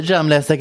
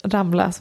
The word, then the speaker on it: Ramlösa: Det är jag som bara ska hälla upp lite Ramlösa granatäpple. – Som vi för övrigt bara vill meddela hela Sverige om att uttalas Ramlösa och inte Ramlösa.